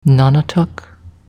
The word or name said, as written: Nonotuck